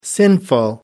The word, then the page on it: sinful: American English